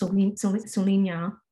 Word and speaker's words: Souligna: It's, you know, it's pronounced Solinha. It's Thai.